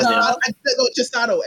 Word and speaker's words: Chisato: Let me tell you, I just love characters like her name is Chiatsu. Is that how you pronounce her name? – Chisato. I'm like, I can't remember. Yes. Chisato. I can't remember. – Chisato. I think it is Chisato.